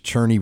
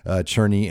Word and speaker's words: Cerni: Now, it looks like it's pronounced Cerni. The proper pronunciation is Cerni. We're going to hear from Daniel Cerni in our next segment, as I had a chance to talk to him from Melbourne, Australia.